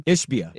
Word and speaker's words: Ishbia: Ishbia.